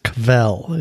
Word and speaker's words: kvell: There's a Yiddish word that is pronounced kvel. If you were to spell it, I guess in English, I guess it would be K-V-E-L-L.